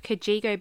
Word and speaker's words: Cajigo: But yeah, if you've never heard of Kajigo before, it's spelled C-A-J-I-G-O.